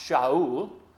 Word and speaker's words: Shaul: Sometimes it's pronounced Shaul. I used to work for a man named Shaul. And it means asked, A-S-K-E-D.